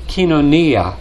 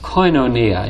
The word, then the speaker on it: koinonia: But the word fellowship is a translation of the Greek word kinonia. It's also pronounced koinonia, and you've probably heard it pronounced that way.